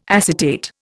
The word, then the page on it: acetate: Indian